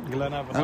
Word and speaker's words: Glentoran: Ja, uh, Crusaders tegen, hoe spreek je dat uit? Glen Evan. – Glen Evan uit uh, eh, Lurgen.